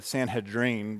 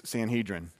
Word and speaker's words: Sanhedrin: The main thing that we need to know is that these guys were part of what's called the Sanhedrin, and I think it's actually pronounced the Sanhedrin, but since I'm I speak American, then it's Sanhedrin.